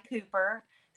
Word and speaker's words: Cooper: My name is spelled funny. Hopefully that will help you remember that. The Wyndi Cooper, COOPER.